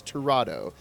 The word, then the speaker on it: Tirado: And I'm pronouncing that right because I asked her how to pronounce her name. It is Tirado.